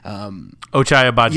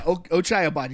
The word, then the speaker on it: Ochai Agbaji: And so the only way he could have done better defending, I can't ever say his name correctly, but Ochai Agbaji. – Yeah, Ochai Agbaji.